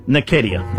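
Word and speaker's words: Osei-Nketia: Eddie Osinecki. Is that how we'd say? He's a Kiwi, yeah. How do you say it? Nikedia, thank you.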